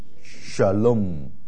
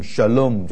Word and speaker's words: shalom: 要 number three，两个音节，第一个音节怎么读啊？shalom， shalom，啊，所以第一个是长母音 sh，第二个是 lom， shalom 就是 peace。所以你去到以色列啊，你什么都不会讲，没关系，就讲 shalom 啊，平安 shalom 啊啊啊 peace。